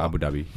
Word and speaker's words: Abu Dhabi: Abu Dhabi, ja.